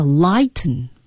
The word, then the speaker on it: lighten: 英文中这个 lighten 啊，它有一个喉音停顿 lighten 它会这样读 light up。